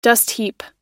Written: dustheap

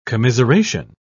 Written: commiseration

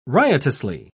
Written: riotously

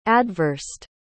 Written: adversed

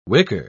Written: wicker

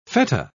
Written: feta

